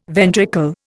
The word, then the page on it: ventricle: Indian